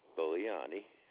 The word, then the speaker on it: Bolognani: John, and that's spelled J-O-N, Bolognani, B-O-L-O-G-N-A-N-I.